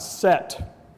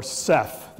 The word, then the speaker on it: Set: Now, another individual who's born at the same time is the god Set, or Seth. There are two different pronunciations depending upon which part of Egypt you're living in.